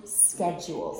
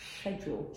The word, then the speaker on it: schedule: And then the one that I prefer the American pronunciation of, I stick with the American here, is schedule. – Schedule, yes, that's how I say it, schedule. – Mm. And British people say schedule.